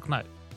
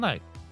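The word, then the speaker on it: Knight: Никто же не говорит, а Майкл Кнайт. Все говорят, Майкл Найт. А пишется Кнайт.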